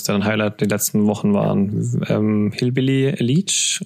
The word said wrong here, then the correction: Hillbilly Elegy: oder wie spricht man das aus? – Ähm, Hillbilly Elegy ist ein autobiografisches Buch von J.D.